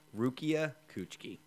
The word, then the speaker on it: Rukia Kuchiki: His life is there, then changed forever when he meets Ruchi. I butchered that name so hard. I apologize. Rukia Kuchiki. – Okay, so. – I tried to apparently mix the two names.